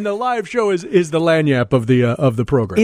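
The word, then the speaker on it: lagniappe: So this is like an accoutrement, or as they say in New Orleans, lanyap, little something extra. – Lanyap. – Lanyap. It's spelled crazy. L-A-G. I, and there's a couple P's in there, an E thrown in, but lanyap is how it's pronounced, and it means a little something extra.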